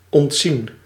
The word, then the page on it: ménager: France; /me.na.ʒe/